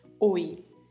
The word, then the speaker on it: hoy: Também é escrito no início com a letra H, que não tem som, e ao final com a letra Y, que pronuncia-se também com som de I.